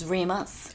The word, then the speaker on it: Ramos: It's like famous, but it's Ramos.